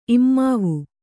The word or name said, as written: ಇಮ್ಮಾವು